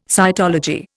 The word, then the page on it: cytology: Indian